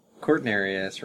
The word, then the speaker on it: Cortinarius: Cortinarius, the mushroom, has the toxin arelanine that causes renal toxicity, because Cortinarius rhymes with Sagittarius,